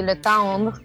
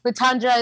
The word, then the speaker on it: Letondre: Yeah, so my last name is hard to pronounce for English speakers because of the R. Right. So normally you would say Letondre, but not a lot of English people could say that. So Letondre is fine.